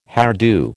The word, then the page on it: hairdo: American English